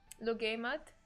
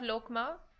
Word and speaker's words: Luqaimat: But it's got a Q in the spelling. Yeah, I know, but it's pronounced Lugaymat. – Not Lokma? No. Not Logma? No.